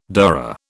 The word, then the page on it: dhurra: British English